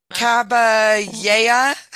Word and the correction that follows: Caballé: Montserrat, M-O-N-T-S-E-R-R-A-T. – Montserrat. – Montserrat. Caballé was famous in which field of the arts? – Oh, man.